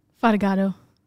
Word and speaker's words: Fatigato: Ashlyn, what's your last name? – Fatigado. – Spell it. – F-A-T-I-G-A-T-O. – Fatigato.